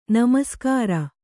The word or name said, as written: ನಮಸ್ಕಾರ